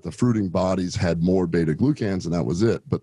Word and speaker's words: beta glucans: You're getting a fraction of the beta glucans that you can.